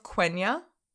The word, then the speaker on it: Quenya: which was written in the Elvish tongue of Quenya. – Oh, you said it right.